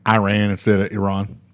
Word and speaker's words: Iran: So like the first thing that the Iran guy said, well, he like grilled him for, he's like, you pronounced the name of our country wrong. It's Iran, not Iran.